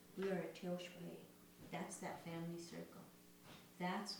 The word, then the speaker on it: Tiyospaye: It's called Tioshpae, and it's spelled T-I-Y-O-S-P-A-Y-E. Tioshpae.